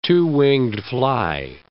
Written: two-winged fly

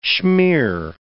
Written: schmear

